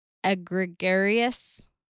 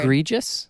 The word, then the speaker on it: egregious: But also, I wonder how much is just normalized nowadays, too, like things that would have been considered egregious. How do you say that word? – Egregious? – Egregious. – There you go.